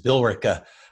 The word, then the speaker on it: Billerica: As I learned before we recorded this and stumbling over the pronunciation of your hometown, you leave out a vowel. It's just Billerica, Massachusetts. Did I get it close? – You got it right.